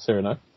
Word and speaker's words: Cyrano: Yeah, so it's pronounced Cyrano.